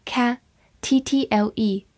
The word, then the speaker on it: cattle: c a 发 ca，t t l e toll，cattle，cattle，美式发音末尾有一点浑浊，也可以读作 cattle。